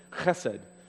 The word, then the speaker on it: chesed: and it's chesed. Pronounce that with extra phlegm at the back of your throat. Chesed.